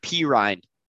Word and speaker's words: Perine: And I'm like, it's pronounced Perine.